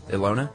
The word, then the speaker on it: Ilona: I was actually shown them by my friend, how would you pronounce I-L-O-N-A? Ilona. – It's like Ilona.